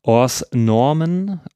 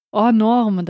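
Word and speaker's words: Hors normes: Im Französischen heißt er Hors Normen, oder ich kann es nicht wirklich aussprechen. Möchtest du das aussprechen? – Also das S wird schon mal nicht ausgesprochen. Hors Norme, dachte ich eigentlich.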